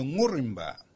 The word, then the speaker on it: Ngurrimba: So if we think about the idea of the ng sound being difficult for English speakers to hear, then perhaps it could be Ngurrimba, Ngurrimba. Now this would make sense in terms of pronunciation.